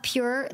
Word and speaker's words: PJUR: I love Pure Lube. It's spelled PJUR.